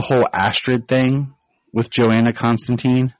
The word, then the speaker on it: Constantine: Yeah, and it was interesting because also they went with the British, I guess, proper pronunciation, where it's Constantine.